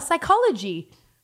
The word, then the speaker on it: psychology: And she was like, oh, psychology. I was like, yes, that's it. – Well, so, were you a good speller? The silent P.